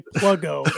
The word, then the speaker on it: Pluggo: For everybody doesn't know, there it's actually Pluggo is how you pronounce it.